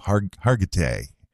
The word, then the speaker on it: Hargitay: Hargitay.